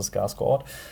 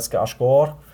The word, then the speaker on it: Skarsgård: Wenn man es beim Schwedischen belässt, da spricht man das D ziemlich sicher mit aus, also Skarsgård. Im Nor Norwegischen wäre es eher Skarsgård.